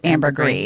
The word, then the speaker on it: ambergris: Well, it has an S, but I think it's pronounced ambergris. – It was like French or something? – Yeah. – Like Pinot Gris.